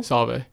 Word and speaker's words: Solvay: Tell me about your days at, is it pronounced Solvay High School? – Solvay. – Solvay. – Yep.